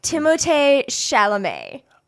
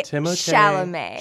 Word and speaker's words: Timothée Chalamet: Timothée Chalamet. – Say that one more time. Timothée, Timothée Chalamet.